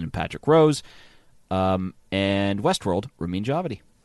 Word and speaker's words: Ramin Djawadi: Dramatic score for a series, Game of Thrones, Ramin Djawadi. I said it right that time.